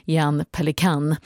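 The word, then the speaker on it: Pelikán: En student som också tog fiollektioner av Dvorsaks kompis Jan Pelikan. Ja, han hette så, men det uttalas väl egentligen mer Jan Pelikan.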